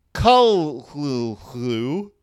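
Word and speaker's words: Cthulhu: but it's something like k-l-l clue. Kul-hlu-hlu, which the first syllable, with the first syllable pronounced gutturally and very thickly. Hull. The U is about like that of full. Hull. And the first syllable is not unlike klul in sound, since the H represents the guttural thickness.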